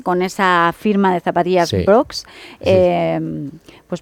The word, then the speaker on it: Brooks: Bueno, pues la marca se llama Brooks.